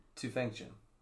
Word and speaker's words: Tufenkjian: Tufenkjian.